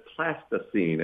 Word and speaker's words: plasticine: That's plasticine, as in plastics, not Pleistocene, which is, I thought he was mispronouncing it when he first said it.